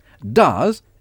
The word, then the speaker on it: does: Does s'écrit D-O-E-S. – D-O... – D-O-E-S. Mais ça se prononce does.